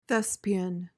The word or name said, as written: thespian